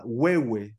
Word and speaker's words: Huehue: Huehuetenango e a gente chama Huehue. Se escreve assim com H-U-E, H-U-E.